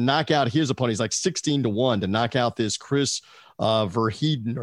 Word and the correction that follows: Van Heerden: or however you say his name. – Chris Van Heerden. – I was close.